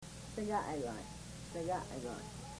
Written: segaagoy

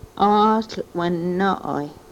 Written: oohl we-nooy

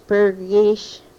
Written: prgish

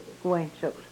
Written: ku wenchokws

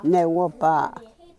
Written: newopa

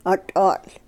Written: o tohl

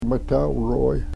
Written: metahweroy

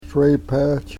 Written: srepech

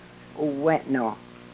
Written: u-wenoh

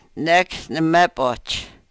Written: nek ne-mepoch

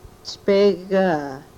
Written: chpegaa

